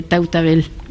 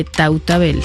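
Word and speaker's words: Tautavel: Pues Amélie, muchísimas gracias por esta, por este reportaje aquí en el Museo del Hombre, para hablar de este hombre, yo lo pronuncio al español, del hombre de Tautabel.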